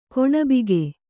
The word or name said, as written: ಕೊಣಬಿಗೆ